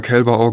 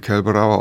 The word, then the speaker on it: Kälberau: Ja, ich habe schon immer Kälberau gesagt, weil meine Mutter war ja Deutschlehrerin und ähm, die hat da schon Wert drauf gelegt, dass der fränkische Akzent, weil ich bin ein gebürtiger Bamberger, nicht so sehr durchkommt. Aber ähm, das hat man durchaus auch dann Kelberauer aussprechen können, ja.